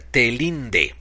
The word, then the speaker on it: TeLinde: Se escribe en español, se, se pronuncia Telinde.